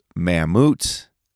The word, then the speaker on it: Mammut: Again, it's pronounced Mammut.